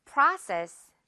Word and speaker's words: process: 우리 이것도 한국말로 할때 프로세스라고 많이 하는 것 같아요. 프라 아 발음으로 고쳐줘야 됩니다. 자, 듣고 따라해 주세요. process.